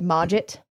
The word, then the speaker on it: Mogget: It's M-O-G-G-E-T. And I've always said Mogget.